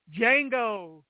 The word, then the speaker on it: Django: That is one of my favorite spaghetti western trailers of all time just because they had that line, Django, the D is silent.